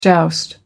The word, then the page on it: joust: American English